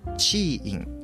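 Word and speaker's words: 知音: 出演はギターアンサンブルチーイン、知るという字に音と書いてチーインと生まれるそうですね。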